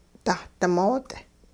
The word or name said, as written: dahdimoot'